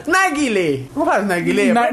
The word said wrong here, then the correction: Narguilé: Sua pronúncia foi muito ruim, o pessoal pode achar que é Naguilé, não é Naguilé. É o Narguilé.